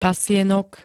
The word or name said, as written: pasienok